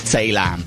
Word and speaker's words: Taalam: T-A-A-L-A-M. Tay Lamb. I presume that's how you pronounce it.